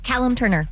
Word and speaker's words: Callum Turner: Callum Turner.